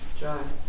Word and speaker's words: Dror: Dror. – Dror. Which is one of the hardest Hebrew words to pronounce. – Two rashes together like that.